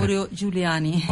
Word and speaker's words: Mauro Giuliani: Mauro Giuliani.